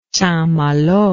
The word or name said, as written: St Malo